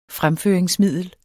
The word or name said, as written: fremføringsmiddel